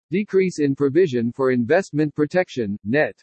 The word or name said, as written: DECREASE IN PROVISION FOR INVESTMENT PROTECTION NET